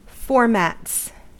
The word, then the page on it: formats: American English